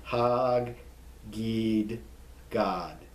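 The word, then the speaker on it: Hor Haggidgad: You pronounce every consonant there. Hag-gid God.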